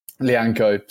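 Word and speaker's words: Lyanco: That clearance off the line was pretty sensational from Linkow, I think is how you Lianco. Pronounce his name. Lianco, sorry. And then the handball shout as well, like you absolutely have seen them given.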